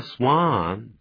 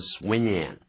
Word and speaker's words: swan: swan, here and gone. 就好像呢天鹅一样。这个天鹅啊可不要念成 swinging, 我叫 swan.